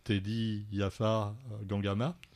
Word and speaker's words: Teddy Yafar Gangama: Et bon, il s'est vaguement excusé, mais pas assez pour certains de nos amis auteurs, comme Teddy Yafar Gangama, je prononce bien. – Oui, c'est ça, oui.